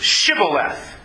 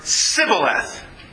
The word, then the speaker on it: Shibboleth: You see, those from Gilead pronounced the word Shibboleth. Those who were from Ephraim pronounced the word Sibboleth.